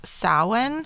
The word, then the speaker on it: Samhain: So they're linked to a Celtic festival called Samhain, and it's spelled S-A-M-H-A-I-N.